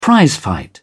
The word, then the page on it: prizefight: British English